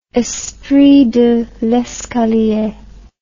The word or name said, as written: espritdelescalier